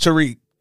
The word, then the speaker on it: Tariq: When you go back to season one, and then all the other seasons of OG Power, yeah, she's never pronounced Tariq correctly. – Tyreek. – Bro. Tyreek every time, and she don't give a fuck, and who gonna tell her? – Yeah.